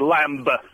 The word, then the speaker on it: lamb: It's the B on the end of lamb, and I'm afraid it's a really dull answer. – Oh. – It's a very old word, lamb, as it's pronounced in Anglo-Saxon or Old English.